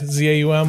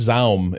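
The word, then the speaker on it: ZA/UM: Is that how we pronounce it? I've never had to like actually say it out loud before. – Well, the slash makes Zaum impossible, right? – I say Zaum. – Yeah, Zaum. Zaum.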